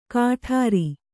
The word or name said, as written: ಕಠಾರಿ